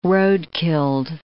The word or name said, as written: road-killed